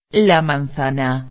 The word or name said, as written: la manzana